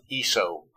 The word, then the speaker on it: Esao: It's spelled E-S-A-O, but it's pronounced Esau.